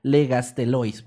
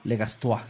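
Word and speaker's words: Le Gastelois: Alphonse Alphonse Legastelois, para que sepan. Es que no sé. – Creo que es Legastois. – Sí, creo que lo dije bien la primera vez.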